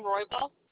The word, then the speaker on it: Roybal: My name is Deanne Roybal. – Can you spell that last name, please? – Sure, R-O-Y-B-A-L.